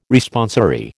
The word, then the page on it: Responsory: American English